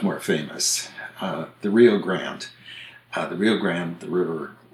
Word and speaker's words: Rio Grande: But there is a town in Ohio where they pronounce the name Rio Grande.